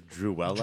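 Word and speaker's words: Duela: is I think her name is Druella. – Druella, yeah. – Which rhymes with Cruella.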